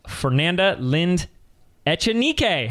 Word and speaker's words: Fernanda Lind Echenique: They're all special, but today's special because I have my friend and coaching client, Fernanda Lind Echenique.